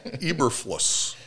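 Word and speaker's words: Eberflus: Eberflaw? – It is Matt. I can say Matt. Eberfluss.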